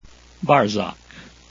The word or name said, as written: barsac